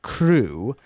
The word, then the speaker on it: CHRU: Now, the Carolina Harm Reduction Union, known as CHRU, this is pronounced CRU, is a student-led program that provides life-saving harm reduction resources to the public of Chapel Hill.